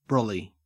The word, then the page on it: brolly: Australian English